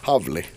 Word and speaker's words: Hawley: Ja, det är svårt hur man ska Howly, Jag tror att det är Howly. Havli, så ska det uttalas.